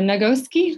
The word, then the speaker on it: Nagoski: You guys can't see it, but there's a book called Come As You Are by Emily Nagors- uh, Nagoski.